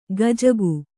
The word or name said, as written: ಗಜಗು